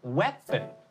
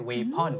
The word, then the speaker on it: weapon: ถูกครับไม่ใช่ lion แล้วคำนี้นะครับ weapon ถูกไม่ใช่ weapon